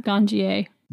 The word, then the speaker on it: Ganjier: How do you spell that, Ganjier? – G-A-N-J-I-E-R, Ganjier.